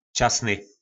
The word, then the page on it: Časny: Raný